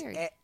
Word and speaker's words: Shari: And crazily, Super Angel, Shari Eubanks, it seems like Sherry. She would have written. Is it Shari? – It's Shari. S-H-A-R-I. And Napier pronounced it Shari.